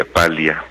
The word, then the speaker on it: Paglia: Dicen que como es italiano se pronuncia Paglia, pero yo creo que voy a seguir diciendo Camille Paglia.